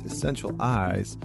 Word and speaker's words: Essentialize: Joining me today is Lee Chambers, who is an environmental psychologist, well-being consultant, and founder of Essentialize, pronounced Essential Eyes, workplace well-being.